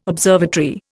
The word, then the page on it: observatory: Indian